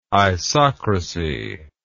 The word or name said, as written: isocracy